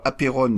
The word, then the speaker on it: apeiron: Alors apérone, je l'écris en transitération, ça s'écrit A-P-E-I-R-O-N.